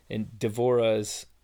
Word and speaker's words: Devorah: It's a common name in Jewish as well as in Devorah is how it's pronounced in Israel.